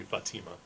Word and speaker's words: Fatima: But Hispanic pronunciation would be Fatima.